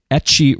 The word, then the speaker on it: Etxe: It comes out on May 5th through Etchy Records, which is spelled E-T-X-E, and I looked up how to pronounce it because it's a weird word, but it's awesome.